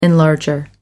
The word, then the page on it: enlarger: American English